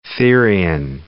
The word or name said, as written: therian